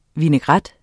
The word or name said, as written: vinaigrette